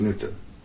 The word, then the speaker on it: Knutson: like English major says, he keeps telling me it's Knutson. – It's Knutson. The K is silent.